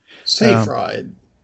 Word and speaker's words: Seyfried: It is Seyfried, right? That's how you say it. – Seyfried.